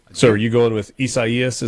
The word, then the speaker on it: Isaias: Right now, the timeline, somebody said that it's, Caleb in Starkville says that this is pronounced Isaias, the Spanish and Portuguese pronunciation for Isaiah.